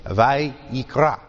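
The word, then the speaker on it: Vayikra (ויקרא): It's Vayikra.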